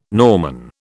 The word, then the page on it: Norman: British English